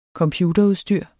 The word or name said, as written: computerudstyr